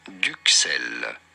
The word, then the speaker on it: duxelles: For reference, in French, in France, we pronounce it as duxelle. The S is silent at the end, so do not pronounce it.